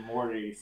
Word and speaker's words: Mordie: I shortened it to Mordie so people can actually pronounce it.